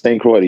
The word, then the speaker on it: St. Croix: But it says that you were born in St. Croix. Have I pronounced it correctly? – Yes, St. Croix, the U.S.